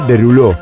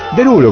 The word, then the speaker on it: Derulo: Tiene una ortografía bastante compleja, es un apellido francés y se pronunciaría en rigor de edad Derulo, pero es Derulo como se lo conoce, porque bueno, obviamente esto tiene que ver con la pronunciación estadounidense.